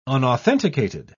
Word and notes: unauthenticated: British English

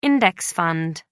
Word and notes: index fund: American English

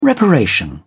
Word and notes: reparation: British English

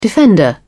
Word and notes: defender: British English

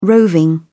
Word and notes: roving: British English

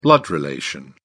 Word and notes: blood relation: British English

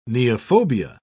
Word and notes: neophobia: British English

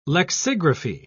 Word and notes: lexigraphy: British English

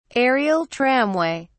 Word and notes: aerial tramway: British English

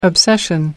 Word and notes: obsession: American English

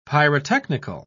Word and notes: pyrotechnical: British English

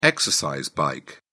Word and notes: exercise bike: British English

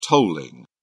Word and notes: tolling: British English

